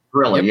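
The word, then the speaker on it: Grilli: Oh, the other one, Jason Grilly? I don't know if I'm pronouncing that right for the Rangers. – You're right, Jason Grilly, yeah. – Grilly, yep. Yeah.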